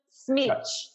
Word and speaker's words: Smycz: Smycz. – Smycz. – It's like S-M-itch.